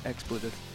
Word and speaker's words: expletive: Yeah, you better have a lot of tomatoes because I'm going to give you an explicitive. – Oh, did I say it right that time? – Yeah, yeah. I think so. – No, expletive.